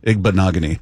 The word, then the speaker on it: Igbinoghene: And then also, if I'm Nehemiah Pritchett or Noah Igbinoghene, is that how you say it? – Yep. – Igbinoghene.